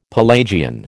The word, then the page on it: Pelagian: American English